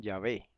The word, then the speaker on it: Yahweh: Dirían Yahvé, probablemente no dirían Yahweh. Porque en hebreo moderno la wow suena así.